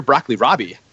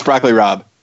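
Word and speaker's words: broccoli rabe: How do you pronounce broccoli Rob? – It's broccoli Rob.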